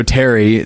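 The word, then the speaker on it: Thierry: He's French, and so Terry. That's how people pronounce it, but it's T H I E R R Y.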